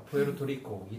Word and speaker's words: 푸에르토리코: 이분이 푸에, 푸에르토리코, 나 아, 발음도 어렵다. – 푸에르토리코, 이초신이고, 그 다음에, 그 다음에 이분이 또 여성이잖아요.